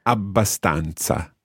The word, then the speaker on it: abbastanza: Okay, always remember to bounce on that. Abbastanza.